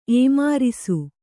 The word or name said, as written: ಏಮಾರಿಸು